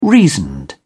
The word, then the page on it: reasoned: British English